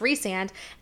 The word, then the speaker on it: Rhysand: or Rhysand, as I called him for the first four books until I learned that his name was supposed to be pronounced Rhysand.